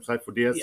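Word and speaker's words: Sifo-Dyas: And then the Prime Minister tells him to tell Master Sifo Dyas, is that how you say his name, Sifo Dyas? – Yeah.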